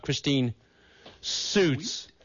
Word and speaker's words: Christine Suits: My guests tonight are the one and only Mr. John Mell and Christine Suits.